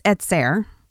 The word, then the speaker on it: ezer: Because when in Genesis 2:18, when it's saying that I will find a helper fit for him, the word is etzer, probably completely destroying that word, but that's kind of the way you would pronounce it, E-Z-E-R.